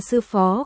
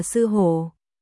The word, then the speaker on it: sư phó: Biểu tình phá lệ kích động, Sở Ngôn biểu tình lúng túng, sửa chữa người này không chính xác phát âm, là sư phó không phải là sư hổ.